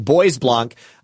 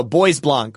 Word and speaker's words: Bois Blanc: Thank you to Bois Blanc, who says, "Can anyone tell it's pronounced Bois Blanc? LOL, it's French. I was thinking like Boise, Idaho." Okay, so Bois Blanc, Bois Blanc.